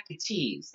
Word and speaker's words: Gaetz: I'm sorry, Matt Gates. That that's how it's pronounced.